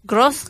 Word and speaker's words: grosse: Oh, you say the S's. Grosse graisse grasse.